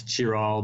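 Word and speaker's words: Chiral: It's C-H-I-R-A-L, which, of course, we pronounce Cure-All, but in Japanese it's Kidaru.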